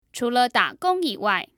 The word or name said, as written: chule dagong yiwai